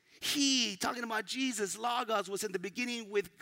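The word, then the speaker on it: logos (λόγος): Now that word in Greek is pronounced logos.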